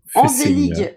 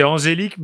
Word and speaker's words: Angélique: Fessienia, Envelig. Envelig, c'est chaud quand même. E-N-V-E-L-I-G. – C'est angélique, mais très très mal prononcé.